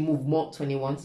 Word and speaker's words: Mouvement: but it's actually movement21, so it's spelled the French way.